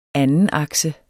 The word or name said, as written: andenakse